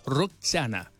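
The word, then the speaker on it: Rosanna: Linda canción de Toto Rosana, 13 horas con 17 minutos. Roxana es como lo pronuncia exactamente el vocalista de esta banda espectacular.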